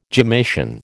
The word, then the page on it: gemmation: American English